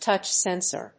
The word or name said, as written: touch sensor